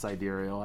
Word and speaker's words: sidereal: I think it's pronounced sidereal. That's how I've always heard it.